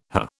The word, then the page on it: hah: British English